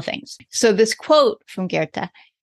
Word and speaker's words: Goethe: And you have this quote from, and I will admit to everyone that I might be butchering this name, but Goethe, I believe is how we say it.